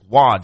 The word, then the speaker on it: Roy: After his rookie year, because then he wore a Patrick Waugh jersey. But Waugh is spelled Roy. – R-O. Yeah, yeah, it looks spelled like Roy.